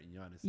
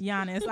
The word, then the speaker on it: Giannis: Let me pronounce his name right. Giannis. – Giannis. I was about to say it's Giannis.